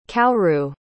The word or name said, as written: kaoru